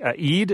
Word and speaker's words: Eid: Eid, am I saying that right? The festival after Ramadan ends? – That's right.